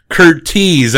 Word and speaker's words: Curtiz: Curtiz. I keep getting corrected on the pronunciation of that director's name.